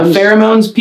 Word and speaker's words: pheromones: Yeah, pheromones, P-H-E-R-O-M-O-N-E-S. – How do you spell it? – P-H-E-R-O-M-O-N-E-S.